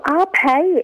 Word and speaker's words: Ape: It's called Arpe Yakitori Bar, and that's how you pronounce it, spelled A-P-E.